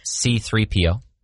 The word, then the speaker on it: C-3PO: We have 3CPO. I know that one. – Nope. C-3PO. – I flipped it around.